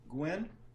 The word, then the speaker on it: Guinn: Guinn.